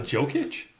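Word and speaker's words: Jokić: Nikola Jokic. – Your eye just twitched like that. – And Jeremy Grant is phenomenal. Name? – Is it Jokic? – Jokic. – Jokic. – Jokic. – How do you spell it? – J-O-K-I-C.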